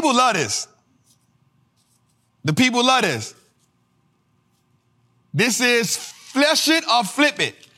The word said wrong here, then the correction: Flush It or Flip It: I didn't say it right? What I said? This is Flush It or Flip It. Man, get out of my face, Jose. Flush It or Flip It, y'all.